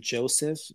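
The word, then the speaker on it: Joesef: Mm, you, I think you pronounce the artist name Joseph, J O S E E F. J O E S E F.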